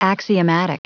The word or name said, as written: axiomatic